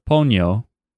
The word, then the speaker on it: Ponyo: but before we break down and show our feelings about Ponyo with a long O, we need to first take a stop.